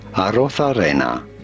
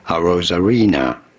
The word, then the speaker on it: Arrozarena: In Spanish, his name would be said as Arrozarena. But in English, or in America, it is generally said as Randy Arrozarena.